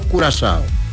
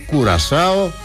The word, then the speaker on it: Κουρασάο: Είναι εντυπωσιακό αυτό με το Κουρασάο. Τονίζω το κου, γιατί πολλές φορές το μπερδεύουμε ή έτσι όπως το λέμε φεύγει η γλώσσα και λέγε, το προφέρουμε Κουρασάο και δεν είναι ωραίο, έτσι.